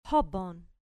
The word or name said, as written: pobon